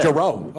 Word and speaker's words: Giroux: Giroux. Oh, yeah, Giroux.